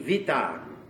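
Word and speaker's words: vita (βήτα): Notice I pronounce this V, vita. Here's the B sound in the Greek language. Okay? That's the B sound. This is not a B sound, it's a V sound, like vita. So it's not alpha, beta.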